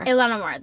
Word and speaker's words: Ilhan Omar: Yes, and he is specifically targeting, I'm going to say her name wrong. Is it Ilhan? – Ilhan Omar. – Ilhan Omar. That's mm-hmm. what I thought.